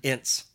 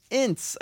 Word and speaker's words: Ince: Ince. – Ince, okay, gotcha, Ince.